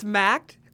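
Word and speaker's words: Mac'd: It's Macked, but I was just trying to pronounce the D. – Yeah. – It's Mac apostrophe D.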